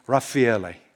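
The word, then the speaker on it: Raffaello: In 1505, the great Italian Renaissance painter, and I love the way the Italians pronounce his name, Raffaele, painted this picture right here.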